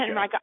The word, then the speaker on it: Henrico: It's Henrico. – Henrico.